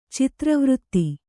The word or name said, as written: ಚಿತ್ರವರ್ತ